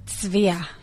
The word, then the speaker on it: Tzvia: So you spell it T-Z-V-I-A. – Yeah. – You pronounce it? – Tzvia. – Okay, so you do hear... – Tzvia. – Okay. – So like you do hear the T-Z-V. – Okay. – It's Hebrew.